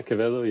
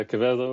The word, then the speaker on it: Quevedo: Is that good, my pronunciation on that? – Absolutely Quevedo. Good. Yeah. Terrific. Yeah, Quevedo. Yeah, Quevedo or Quevedo.